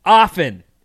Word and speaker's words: often: Often is what I think I say. Often. – And I actually think often is the correct pronunciation.